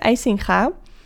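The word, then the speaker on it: Eisinga: So, but in Dutch, it is IJsse IJsinga.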